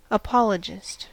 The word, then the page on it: apologist: American English